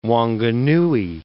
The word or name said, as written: Wanganui